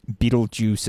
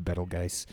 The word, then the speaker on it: Betelgeuse: is Betelgeuse. – Yeah, that's one of them for sure. Yeah, that's another one. – Or Betelgeuse, as the Americans say. I prefer Betelgeuse.